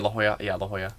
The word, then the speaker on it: La Jolla: No, it started at La Jolla? Is that how you say it? – Yeah, La Jolla.